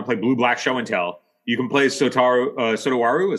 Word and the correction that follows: Satoru: Is that how it's pronounced? – Satoru, yeah.